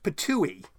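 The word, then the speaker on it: Ptuj: It's pronounced Patooey. P-T-O-O-E-E.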